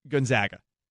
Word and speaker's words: Gonzaga: I don't know what it is about that word, but I have a really difficult time just saying gone. Gone as the first syllable. Like to me it's just it just rolls off the tongue so easily, Gonzaga. And what's also interesting is and this was brought up by Aaron Levine of of, of Fox 13. I've always said Gonzaga correctly, but if the school is named after Aloysius Gon Gonzaga, that's how you're supposed to pronounce it. That's how it's pronounced in Italian.